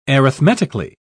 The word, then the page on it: arithmetically: British English